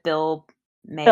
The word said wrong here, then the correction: Maher: Bill Maher. – Bill Maher. – Oh yeah, I don't know how to say his name my whole life.